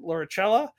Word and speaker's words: Lauricella: But like Will said, we are interviewing Dr. Sharon Lauricella.